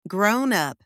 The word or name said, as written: grown-up